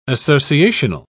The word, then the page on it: associational: British English